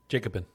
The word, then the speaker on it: Jacobin: And so that article is by, how do you pronounce it? Jacobin? – Jacobin. – Jacobin? – Jacobin, yeah.